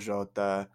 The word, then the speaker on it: Jota: Pronounce the J, like Jose Mourinho, Diego Jota, Gabriel Jesus.